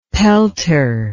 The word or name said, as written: pelter